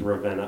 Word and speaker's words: Ravenna: That I was mispronouncing it. Mm-hmm. It's Ravenna, Ohio.